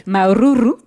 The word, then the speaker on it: Maururu: Maururu, Kenny est là. J'ai bien prononcé. – Super.